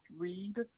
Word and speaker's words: Reid: My name is Patricia Reed, R-E-I-D. – Thank you, Ms. Reed. Excuse me. Yeah, Patricia is spelled P-A-T-R-I-C-I-A.